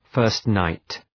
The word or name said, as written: first-night